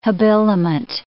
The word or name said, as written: habiliment